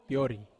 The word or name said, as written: piori